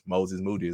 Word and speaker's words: Moses Moody: Yes, I pronounced his name right, Kendrick Perkins. Moses Moody is how you pronounce his name.